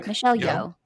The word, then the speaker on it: Michelle Yeoh: Michelle Yeoh.